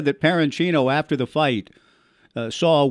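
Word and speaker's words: Perenchio: Is it Jerry Parenchino? Is that how it's pronouned, Parenchino, the promoter? – I believe so. – Okay. – That sounds right. That sounds phonetically correct.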